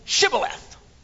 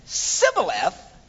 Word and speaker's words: Shibboleth: He says, all right, then say Shibboleth. And if he said Sibboleth, because he could not pronounce the word correctly.